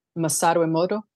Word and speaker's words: Masaru Emoto: Masaru Emoto.